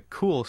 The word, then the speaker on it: Cuil: It's called Cool. It's spelled C-U-I-L.